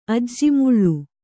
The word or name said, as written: ಅಜ್ಜಿಮುಳ್ಳು